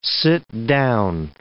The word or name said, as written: sit down